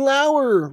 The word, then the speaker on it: Lauer: Because I got to do the review show at Ryan Lauer. Rhymes with tower. Come on, guys. – Spelled like lower.